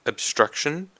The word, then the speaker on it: obstruction: Next we have obstruction. Obstruction. So this time we have the emphasis on the second syllable, obstruction.